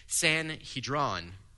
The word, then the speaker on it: Sanhedrin: In every town, they would establish what was called, and you'll be familiar with this term, a Sanhedrin. Now, one of my dear friends who is far better at language than me, he says, nope, that's not how you say it. It's Sanhedron. Fine.